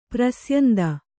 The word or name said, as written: ಪ್ರಸ್ಯಂದಿ